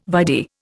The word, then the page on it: vide: Indian